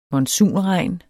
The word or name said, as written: monsunregn